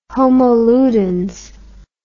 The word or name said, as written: homo ludens